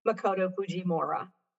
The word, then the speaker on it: Makoto Fujimura: Makoto Fujimura.